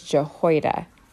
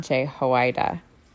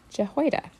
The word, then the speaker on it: Jehoiada: It's Jehoiada, not Jehoiada. Jehoiada.